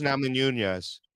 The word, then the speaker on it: Namajunas: Rose Namajunas.